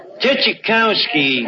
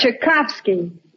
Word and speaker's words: Tchaikovsky: Tchaikovsky. – Archie, that's Tchaikovsky. – So I mispronounced one of them.